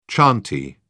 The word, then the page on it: chantey: British English